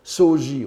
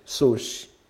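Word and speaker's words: Soshi (荘子): Soshi, qu'on prononçait souvent Soji autrefois, et maintenant euh, la, mode sinologique japonaise tend à, prononcer Soshi.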